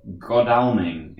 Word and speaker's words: Godalming: In a place called Godalming in Surrey.